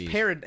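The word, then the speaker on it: Paradis: But then Paradise. Is it parody? Paradise? That's how it's spelled. – I just say it like parody. – I call it Paradise. – They say parody. – I heard it. – It is parody. It's spelled Paradise.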